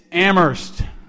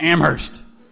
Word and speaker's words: Amherst: It's Amherst. No H. It's a common freshman mistake.